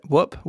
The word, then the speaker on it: Whoop: Is that how you pronounce it? Whoop, Whoop. – That's right. Yep.